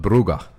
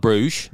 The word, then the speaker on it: Brugge: I've been over there a few times now, but Brugge, that's how you pronounce it. – Okay, so not Bruges, nope. – As some people say.